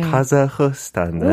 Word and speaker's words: 카자흐스탄: 카자흐스탄 맞죠? – 아, 그렇게 발음해요. – 네. – 카자흐스탄.